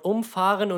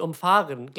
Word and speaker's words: umfahren: Oder das gab es, glaube ich, auch mal, äh, dass das Wort umfahren und umfahren gleichgeschrieben wird, aber, aber komplett gegensätzliche Bedeutung hat. – Stimmt, ja. Umfahren und umfahren. – Ja. Das ist nur eine andere, nur eine andere Betonung.